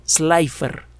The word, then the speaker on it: Slipher: Uno de ellos en 1912 me parece fue firmado por un investigador, Besto Melvin Slifer. Besto se escribe con V y se escribe S-L-I-P-H-E-R. Slifer,